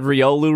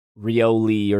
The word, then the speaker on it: Riolu: She said Riolu wrong and somebody corrected her. And she was like, oh, that's how you say it. Cool. Like, she was a very, well, surprisingly, it was not me who corrected her. I think she called it Rioli or something like that.